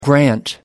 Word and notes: grant: American English

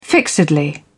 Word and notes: fixedly: British English

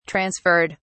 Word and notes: transferred: British English

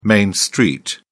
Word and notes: main street: British English

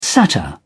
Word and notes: satyr: British English

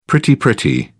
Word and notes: pretty pretty: British English

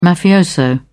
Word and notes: mafioso: British English

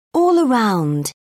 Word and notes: all-around: British English

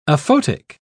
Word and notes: aphotic: British English

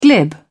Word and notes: glib: British English

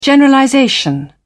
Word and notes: generalization: British English

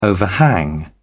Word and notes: overhang: British English

